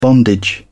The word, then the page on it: bondage: British English